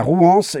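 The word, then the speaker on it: Rouans: C'est à mi-chemin entre Nantes et Pornic, oui, la commune s'appelle Rouance. – Voilà, Rouance, il faut préciser, pas confondre avec Rouen qui est en Seine-Maritime.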